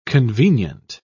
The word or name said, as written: convenient